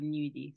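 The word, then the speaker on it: gnudi: A G and an N is new, is nya, or like gnocchi or nudie. So yeah.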